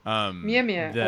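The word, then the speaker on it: Mjölnir: And so, in order for Thor to be able to be worthy of Mjolnir, which I'm sure I'm pronouncing terribly. – Mjolnir also works.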